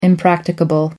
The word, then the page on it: impracticable: American English